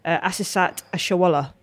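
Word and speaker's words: Asisat Oshoala: Like we said, Beck Smith, part of our team, she went over to Spain, I think, and interviewed Asisat Ashawala. I said that right? Whew. – Well done.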